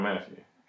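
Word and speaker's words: Mathieu: How do you say his name? Honey Badger. – Tyrone Matthew. – Is it Matthew? Mm-hmm. Yeah.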